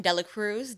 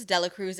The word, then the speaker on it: Dela Cruz: Dela Cruz. Dela Cruz is spelled D-E-L-A-C-R-U-Z.